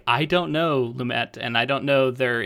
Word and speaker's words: Lumet: I think we both were mispronouncing Lumet before and calling him Lumet.